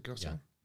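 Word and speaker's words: Gose: I don't know if Gosa beer, is that how you pronounce it, Gosa? – Yeah.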